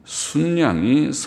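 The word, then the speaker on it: 숫양: 내가 들어본 즉 강가에 두 뿔 가진 순양이 섰는데 순양, 수양이라고 읽지 않고 수에다가 시옷 받침을 해서 표기하는 게 표준말이라고 하고 우리 한국말이 한글도 참 어렵죠. 표준말은 순양 수하고 양하고 두 글자를 합칠 때 뒤에 자가 모음자일 때는 사이시옷을 붙인다.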